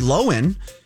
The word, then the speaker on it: Lohan: Well, you guys, first we learned earlier this week that we were pronouncing Lindsay Lohan's name wrong. It's actually Lindsay Lohan.